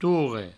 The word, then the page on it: torre: tourre